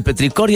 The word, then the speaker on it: petricor: La gota impacta en el suelo poroso y ahí sale el petricor, oye, ¿lo dije bien? – Petricor.